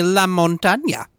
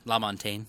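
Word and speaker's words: LaMontagne: How do you actually pronounce his name? – La Montagne. – Do you? – Yeah. – Ray La Montagne.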